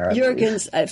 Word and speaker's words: Juergensmeyer: Juergensmeyer, from UC Santa Barbara.